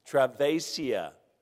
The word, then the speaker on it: Travesia: And I'm not sure I'll say this right, but we also had a young lady, Travis Shia, whoever... what? Travesia. Well, okay. As they said, I put the wrong emphasis on the wrong syllable.